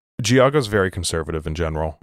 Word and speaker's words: Geauga: How do you pronounce the county? What's the county that's right below Lake? – Geauga. – There we go.